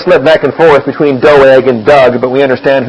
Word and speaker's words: Doeg: Now, one of the servants of Saul was there that day, detained before the Lord, and his name was Doeg the Edomite. I'm very thankful for Stan Newton being here last week to help me out here on the pronunciation of Doeg. I always called him Doug.